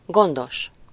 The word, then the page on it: soigneux: France; [swa.ɲø]